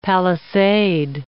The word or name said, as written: palisade